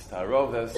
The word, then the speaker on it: taruvos: We have what's called in halacha taruvos, or when it's properly, that's the way it's pronounced in yeshivas, when it's properly pronounced, it's taroves.